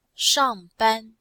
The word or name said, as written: shang4ban1